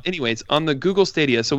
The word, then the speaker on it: Stadia: Stadia, yep.